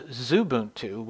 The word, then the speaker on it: Xubuntu: And when I did that, I chose the Ubuntu variant known as Zubuntu, which is spelled X-U-B-U-N-T-U.